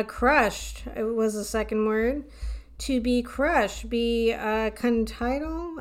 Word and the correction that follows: contrite: I don't know if I'm saying that right. – Contrite? – Contrile? – Contrite. – Contrite. – I think is what it says.